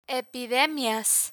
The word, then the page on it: epidemias: Spanish